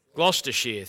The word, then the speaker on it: Gloucestershire: And he was born in the Cotswolds area of Gloucestershire. How do you say that? Gloucestershire, thank you.